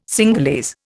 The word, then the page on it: Cingalese: Indian